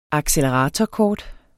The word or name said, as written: acceleratorkort